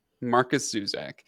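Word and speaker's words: Markus Zusak: Marcus Zusak.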